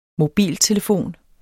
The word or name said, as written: MOBILTELEFONER